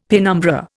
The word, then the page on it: penumbra: Indian